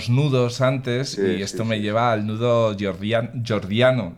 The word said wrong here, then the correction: gordiano: ¿lo he dicho bien? Jordiano. – Gordiano. – Gordiano, sí, eso. Gordiano.